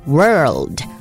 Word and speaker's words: World: 来世界 World 中间是 R 的音，你的舌头要卷起来 World，然后呢战争 War War， So World War One。